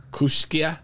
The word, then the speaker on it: Kooskia: K-O-O-S-K-I-A. Kooskia? – Yeah, Kooskia. Yeah. – But then you said, he says Kooski. – That's what the locals say.